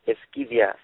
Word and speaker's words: Esquivias: Yeah, my last name is Esquivias. – Esquivias, see? Okay. They were saying Esquivios, and I'm like, Esquivios? It's no Esquivios. Esquivias.